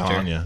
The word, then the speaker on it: Anya: A-N-Y-A. – Y-A. – Like the Gears of War character. – Anya. – Yeah, Anya. – And that's like the way that you pronounce those A's, the same as you pronounce Anastasia.